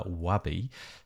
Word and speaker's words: Wabi: One of them is called Wabi, W-A-B-I, and one of them is called Wei. I think I've pronounced that correctly. – Yeah, that's right.